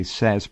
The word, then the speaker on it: Sasse: But look up S-A-S-S-E, Sass, pronounced Sass.